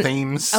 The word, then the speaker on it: Thames: But today you tried to tell me that it was called Tom's River. – Well, I was closer than you. Thames.